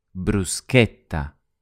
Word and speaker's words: bruschetta: In Italian, we say bruschetta with a k, bruschetta, bruschetta, bruschetta.